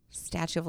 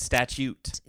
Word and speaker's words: statute: Statue of limitations. So I know it's not statue, but that's how it gets pronounced in sloppy slang. – It's statute. – Yes, with a T.